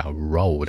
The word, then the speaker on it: road: 你在发这个 road 的音之前把嘴巴撅起来窝一下， road on the road，这样好听多了。